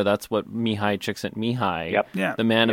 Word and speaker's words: Mihai Csikszentmihalyi: Mihai Csikszentmihalyi.